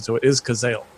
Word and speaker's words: Cazale: Is it John Cazale? Yeah, Cazale.